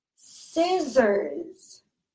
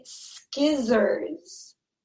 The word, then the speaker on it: scissors: So this C is silent and it's spelled S C I S S O R S. This C is silent. So we do not say skizzers. We say scissors.